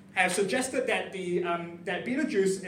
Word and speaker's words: Betelgeuse: And during that tour, we learned about a star called Betelgeuse, spelled Betelgeuse, which just doesn't look like you pronounce it as Betelgeuse. Anyway, it's a star called Betelgeuse.